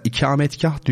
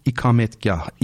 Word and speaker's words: ikametgah: E, mesela ikametgah diyoruz ya mesela o yanlış aslında. Doğru telaffuz ikametgah, ikame etmek çünkü değil mi? Kıyam var ya işte.